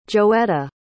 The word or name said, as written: joetta